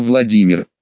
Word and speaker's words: Владимир: Хороший спектакль, сеньор Владимир. По-английски, с легким акцентом и ударением на последнем слоге моего имени, что соответствует старославянской норме произношения, хотя, безусловно, в данном случае это наверняка совпадение.